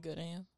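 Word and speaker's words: GO:OD AM: Good AM.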